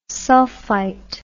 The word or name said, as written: sulphite